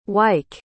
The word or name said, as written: wike